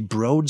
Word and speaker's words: Broad: Husband is an MSU alum, and one of our sons just graduated from what only MSU alums would know is actually pronounced the Broad School of Business.